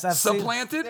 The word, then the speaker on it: supplanted: Giants, have you ever seen anything as ridiculous as Eli Manning getting surplanted for Geno Smith? – Surplanted. – No good? – That's good. Keep going. – Did I say it wrong? – Yes. – Supplanted? – There you go.